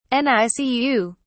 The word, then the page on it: nicu: British English